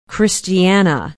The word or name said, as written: Christiana